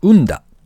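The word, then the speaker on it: 雲駄: と、スニーカーのソールを組み合わせた新しい履物、ウンダ。雲に、おだちのだというか、セッタの田ですね。これでウンだと読むんだそうですが、そういう履物ですね。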